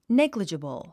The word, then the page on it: negligible: néɡlidʒəbl; ネグリジブル